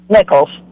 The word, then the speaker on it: Nichols: Nichols. – Nichols. Spell it. – N-I-C-H-O-L-S. – O-L-S.